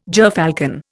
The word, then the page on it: gerfalcon: Indian